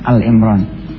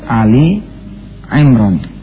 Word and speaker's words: Ali Imran: Biasanya suka bacanya Al-Imran. Padahal bukan Al-Imran tapi Ali Imran, ya.